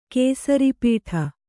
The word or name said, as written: ಕೇಸರಿಪೀಠ